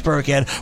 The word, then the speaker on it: Burkhead: But there's no more Burkhead, so... – You know, it's very interesting here because you both kind of said it right. Rex Burkhead is out.